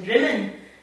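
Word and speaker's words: Drymen: But the strangest experience I had of that was going into a shop in Drimmen, which is spelt dry men, but pronounced Drimmen, but there's plenty of dry men about in Drimmen.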